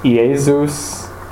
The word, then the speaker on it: Iesus: So it's Iesus, and then Nazarenus is of Nazareth, and then Rex is King, and then Iudaiurum, and that's of the Jews.